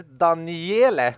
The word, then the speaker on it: Daniele: Sen ritad av, varför jag stakar mig på ett så enkelt namn som Daniel, eller Daniele, eftersom att det är D-A-N-I-E-L-E.